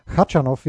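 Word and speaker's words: Khachanov: Jetzt spielt er gegen Kaschanov, der ja Katschanov, wie er, glaube ich, richtig ausgesprochen wird, fünf Sätze gegen Korda.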